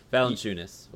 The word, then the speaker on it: Valanciunas: I always said it wrong during the season. Valenzuelas. Valanciunas.